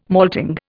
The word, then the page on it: malting: Indian